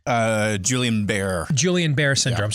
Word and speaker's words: Guillain-Barré syndrome: Guillain-Barré syndrome.